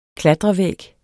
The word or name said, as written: klatrevæg